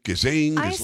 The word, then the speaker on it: Ghislaine: The S is silent, I guess. – Oh. Wow. You really went with silent S on Ghislaine? – I don't know.